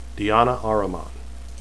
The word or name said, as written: deane